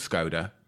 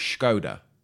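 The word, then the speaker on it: Škoda: Recently, you know, found out that the Skoda is pronounced Skoda. – What? – You know, Skoda. It's called. It's not called Skoda. It's Skoda. – Is it? – Yeah. So all along, people have been saying it wrong.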